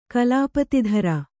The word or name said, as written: ಕಲಾಪತಿಧರ